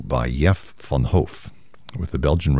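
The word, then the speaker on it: Jef Van Hoof: His name is Jeff, which is spelled like Jeff with just one F. Last name is Von Hoff, V-A-N-H-O-O-F.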